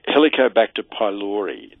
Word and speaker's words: Helicobacter pylori: Helicobacter pylori.